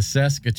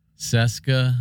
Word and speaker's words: Cesca: C-E-S-C-A. – Look, it's missing a foot over here. – Yeah. Seska, I believe it's pronounced.